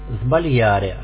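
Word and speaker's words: sbagliare: В начале слова перед звонкой буквой читается как «з». «Сбальяре» – ошибаться.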